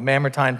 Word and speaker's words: Mamertine: He's waiting in a real place, in a real prison that's called the Mamertine Prison. Excuse me, I said that wrong, the Mamertine Prison.